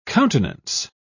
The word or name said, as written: countenance